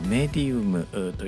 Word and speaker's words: medium: 霊媒を意味する英語をメディウムというふうに発音していこうと思っています。